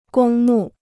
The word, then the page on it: 公墓: gōng mù